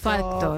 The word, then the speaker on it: fact: Nunca lo había pensado, lo de pronunciar fact, que al final tienes que obviar la C e irte por la T,